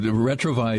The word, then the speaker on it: retrouvaille: Yeah, and Mario, retrovi is a French word. It's spelled R-E-T-R-O-U-V-A-I-L-L-E, pronounced retrovi. And if you'll just do an internet search for retrovi, there will be a national website.